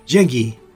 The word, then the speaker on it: Zheng He: ¿Cómo se pronuncia? – Zheng He. – Zhengyi. – Zhengyi. – Acá le pedí a un chino amigo mío que me venga a ayudar con la pronunciación. Zhengyi.